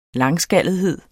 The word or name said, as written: langskallethed